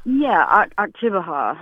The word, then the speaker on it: Oktibbeha: It was like Okatawa County or something like that, or Oktibbeha. – Yeah, there you go.